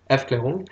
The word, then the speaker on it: Gegenaufklärung: Les antilumières, au contraire, hein, c'est en allemand, on dit Gegenaufklärung, bon, je le prononce pas très très bien, Aufklärung. – Aufklärung, c'est un concept qui a été inventé par, semble-t-il, par Nietzsche.